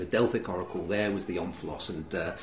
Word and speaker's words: omphalos: I don't know how to pronounce it. How would you pronounce it? – I pronounce it omphalos. – Okay, well, that's good. I've got that. – That'll do for me. I don't claim to be an educated man who knows the correct pronunciations of things. Let's go for omphalos.